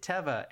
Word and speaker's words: Teva: My family's always called it Teva, and I will continue to do that, but I believe it's Teva. – I saw a commercial recently, and they pronounce, and commercials, I think, are the best way because there's the branding. And they pronounced it Teva,